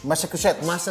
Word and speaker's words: Massachusetts: Mas, mas, mas, gimana sih pronounce-nya? Massachusetts. Massachusetts. – Masat Ma, Mas ya Massachusetts.